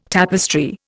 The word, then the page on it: tapestry: Indian